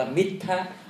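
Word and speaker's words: middha: và thủy miên là mít tha, đọc là mít tha.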